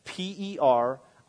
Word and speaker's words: perichoresis: Perichoresis.